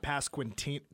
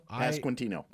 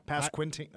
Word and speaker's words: Pasquantino: How do you pronounce his last name? – Pasquatino. – It's not Pasquintino. Pasquantino? – Pasquantino. – Pasquantino. Okay. I pronounce it the same way you do.